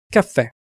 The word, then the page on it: caffe: Italian